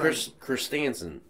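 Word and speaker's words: Krrsantan: Black Kurzistan. – But to me, when Kurstansen. That's how you say it.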